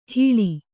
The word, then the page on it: ಹಿಳಿ: Kannada